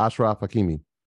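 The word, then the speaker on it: Achraf Hakimi: Yeah, Ashraf Hakimi.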